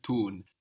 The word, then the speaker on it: tun: Tun. – Tun. Good. No? D to T and then put an N to make it a verb in German. Tun. – T-U-N.